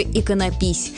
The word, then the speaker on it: иконопись: Так что нужно просто запомнить «иконопись» с ударением на первом слоге.